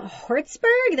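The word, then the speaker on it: Hjortsberg: The writer is William Hertzberg. There's a fucking silent J in that one.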